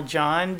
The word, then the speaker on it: John: My name's Colonel John Diaz, and I spell that J-O-H-N, last name spelled D-I-A-Z.